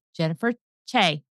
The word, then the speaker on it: Jennifer Choi: I have been interviewing Jennifer Choi.